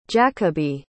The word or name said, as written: jakobe